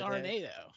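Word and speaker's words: Arenado: It's Arnado. It's a much cooler last name.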